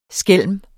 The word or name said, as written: skælm